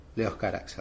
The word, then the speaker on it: Leos Carax: Eh, el director creo que era Leos Carax, sí, lo dije bien, Leos Carax.